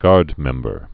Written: Guardmember

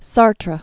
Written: Sartre

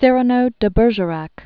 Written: Savinien de Cyrano de Bergerac

